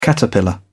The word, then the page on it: caterpillar: British English